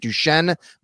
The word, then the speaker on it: Duchenne: Did I say that right, Tommy? Is it Duchenne muscular dystrophy? Is that how you do it? – Yep.